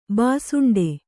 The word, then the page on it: ಭುಸುಂಡಿ: Kannada; bhusuṇḍi